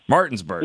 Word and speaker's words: Martinsburg: How do you say that? Martinsburg. – Yeah. – Martinsburg. – Yeah. Got Martinsburg, it.